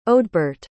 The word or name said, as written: odbart